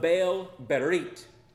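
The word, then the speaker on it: Baal-berith: And then they gave him 70 pieces of silver from the house of Baal-berit. That is not T-H at the end, that H is silent.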